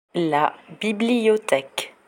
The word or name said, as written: la bibliotheque